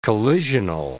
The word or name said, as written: collisional